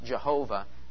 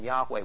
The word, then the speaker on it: Yahweh: Not just God generic, but God, but the Lord Yahweh, sometimes pronounced Jehovah. Well, that's kind of a misreading of the consonants and vowels of two different words that are put together in the Hebrew text. So Jehovah is really not the pronunciation. Yahweh would be closer to the pronunciation.